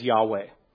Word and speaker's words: Yahweh: The Holy Tetragrammaton, that is the name of God in the Bible that we pronounce as Yahweh.